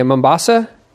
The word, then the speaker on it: Mombasa: And you were born in Kenya, Mombasa.